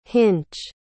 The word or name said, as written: hinch